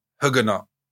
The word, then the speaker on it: Huguenot: In the Huguenot. The Huguenot Wars. – You know you're saying that wrongly. – Huguenots. The Huguenot Wars. – Huguenot. Don't say that. – Huguenot. – I'm saying that right.